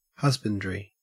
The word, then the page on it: husbandry: Australian English